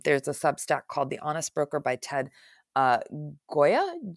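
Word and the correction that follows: Gioia: I, I, think I'm saying his name wrong. G I O I A. Joya.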